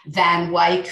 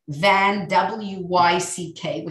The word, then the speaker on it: Van Wyck: I won't go into details with the Van Wyck family, which is the correct way to pronounce it, I learned. Van Wyck, which I always thought was Van Wyck, but no, you know, as in the Van Wyck Expressway, but it's the Van Wyck.